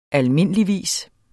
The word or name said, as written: almindeligvis